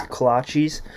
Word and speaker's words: kolache: So it's a small hole in the wall breakfast joint packed with great drip coffee and kolaches or kolaches, kolches. I don't really know how to pronounce them. The way they were pronouncing was kolaches.